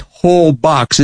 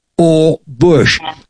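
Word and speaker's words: Holbox: It's H-O-L-B-O-X. It looks like whole box. It's pronounced, the H is silent. It's pronounced Olbox.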